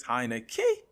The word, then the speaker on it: Heinicke: like Chase Young says, Heinicke. You got an emphasis on the key.